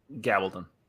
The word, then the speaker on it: Gabaldon: The gentleman who does the Outlander series, Diana Gibbon, has even said, I think that's how you pronounce Gabaldon. It. – Gabaldon.